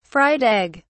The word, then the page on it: fried egg: British English